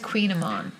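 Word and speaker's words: kouign-amann: That word is Queen Amon. – Queen Amon. That's how you say Queen Amon? – It's spelled like Koigen Ammon.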